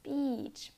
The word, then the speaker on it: beach: You almost smile while pronouncing it. It's not bitch. This is a slang word really, really bad or offensive. It's beach. Pay attention to the vowel sound. E.